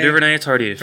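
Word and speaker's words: Duvernay-Tardif: Wait, how did you, how did you pronounce that name? – L- L- Laurent Duvenard. Ted Tardif. – That's close or, enough. Duvernay, du- Duvernay Tardif. Tardif. Yes.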